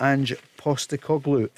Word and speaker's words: Ange Postecoglou: What can you tell us about Ange Postecoglou? And is that the way we should pronounce it? – Yeah, you're spot on.